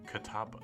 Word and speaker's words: Catawba: How do you say it? – Catawba.